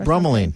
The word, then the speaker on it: bromelain: Bromonide or bromelain.